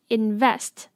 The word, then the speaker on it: Invest: Invest 要注意它重音的部分 Invest 是在 Vest 才是重音哦